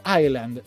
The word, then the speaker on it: Island: Tiene una S, pero se pronuncia Island, la palabra Island. No sé por qué le ponen una puta S si no se pronuncia.